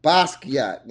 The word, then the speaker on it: Basquiat: Basquiat.